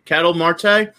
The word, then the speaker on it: Ketel Marte: It's Marte, Ketel, I want to say his name is, or Ketel, not really. Ketel, Ketel Marte. Yep.